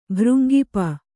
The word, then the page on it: ಭೃಂಗಿಪ: Kannada